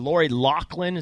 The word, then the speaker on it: Loughlin: Let's just start, I guess, with the Lori Loughlin. Is that how you say her name? – Yep.